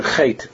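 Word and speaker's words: chait (חטא): Because even like the Baal Shem Tov taught that in the word chait, the aleph is silent.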